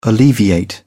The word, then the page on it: alleviate: British English